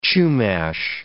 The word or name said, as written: Chumash